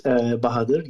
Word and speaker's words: Bahadir: This is Bahadir.